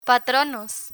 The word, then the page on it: patronos: Spanish